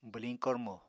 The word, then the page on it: bwliñ kormo: apatani